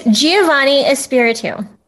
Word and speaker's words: Giovannie Espiritu: Okay, it's Giovannie Espiritu.